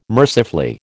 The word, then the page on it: mercifully: American English